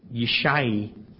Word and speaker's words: Yishai: Obed begot Jesse, which is pronounced Yishai in Hebrew.